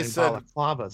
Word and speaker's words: balaclava: when they're wearing balaclavas. – Balaclava. – I like how they're wearing baklavas on their face. – Baklava. Okay, my Middle Eastern friends would even correct me on the way I pronounced it.